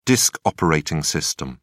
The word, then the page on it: disk operating system: British English